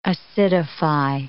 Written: acidify

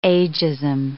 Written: ageism

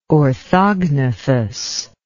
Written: orthognathous

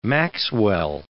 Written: maxwell

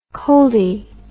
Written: coldie

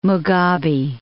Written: Mugabe

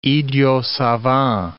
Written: idiot savant

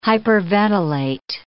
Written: hyperventilate

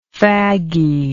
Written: fagginess

